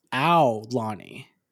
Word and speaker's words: Aulani: It's actually something I learned. It's not Alani. That's technically incorrect pronunciation. It is Alani. – It's Aulani. – Aulani. – Aulani. – Aulani. – Aulani. – That's a soundbite I'm saving right there. So it's Ao, like O-W-W, Ao.